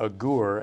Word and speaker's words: Agur: In these verses, as well as in all of chapter 30 of Proverbs, we have the sayings of Agur, or Agur, as more properly pronounced.